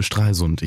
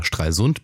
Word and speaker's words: Stralsund: Ich habe geschrieben ein bisschen und ähm, ja, war ich war ein paar Tage war ich dann in Stralsund. Ich habe jetzt gelernt, man betont es auf der ersten Silbe. Ich habe es immer Stralsund betont, man betont es aber auf dem also man betont es vorne.